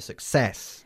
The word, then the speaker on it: success: The success of the success. Enfatizando la segunda sílaba.